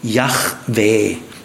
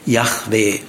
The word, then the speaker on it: Yahweh: Das Wahrscheinlichste ist Yahweh. Ton auf der zweiten Silbe, Yahweh.